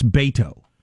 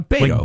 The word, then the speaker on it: Beto: Beto. I want to say it correctly. I'm not trying to be dismissive. It's Beto. – Well, yeah, Beto. – Like Beethoven. – Yes. – That's how I'll remember it.